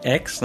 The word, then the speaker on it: X: Bom, o tema dessa semana vai ser o lançamento de Xenoblade Chronicles Cross, ou pro pessoal do ocidente aqui, X, né? – É que eu descobri isso agora, gente. Então, desculpa pelo Cross. O Cross é como se escreve no japonês. X, eu descobri vendo um vídeo da Nintendo, que eles chamam aqui de X.